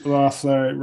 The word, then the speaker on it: Loeffler: Is that how you pronounce her name? Am I saying that right? – Yes. Loeffler rhymes with loser.